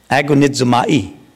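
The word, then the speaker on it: Agonizomai (ἀγωνίζομαι): Agonizomai. Agonizomai. Agonizomai. That's the better way.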